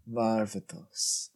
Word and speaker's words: Varvatos: John Varvatos? Varvatos? – Varvatos.